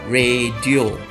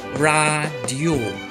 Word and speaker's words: radio: R A D I O 要怎么念呢？一般我们都会念成 radio，但是在马来文里面呢，我们一定要根据 sebutan baku，在这里我们应该念成 radio。